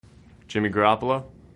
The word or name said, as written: Jimmy Garoppolo